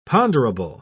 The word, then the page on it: ponderable: British English